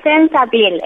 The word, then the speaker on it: Sense Appeal: Sí, es Sense Appeal. Es como Sense Appeal, ¿viste? Esa palabra que por ahí es más conocida, pero con Sense en vez de Sex.